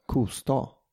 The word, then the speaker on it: Kosta: Kosta är ju liksom, du uttalar ju aldrig sta. Alltså du uttalar ju sta. – Ja, alltså i de... – Jaha, säger de inte sta? I Småland. – Kosta. – Kosta. Säger de väl inte? – Kosta, ja.